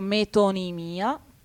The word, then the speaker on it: metonimia: Per la pronuncia si può dire in entrambe le maniere, deriva dal greco metonimia, per cui magari se uno volesse fare un po' il figo e essere più corretto potrebbe riprendere la pronuncia greca che ha appunto la O lunga e l'accentazione acuta sull'ultima I.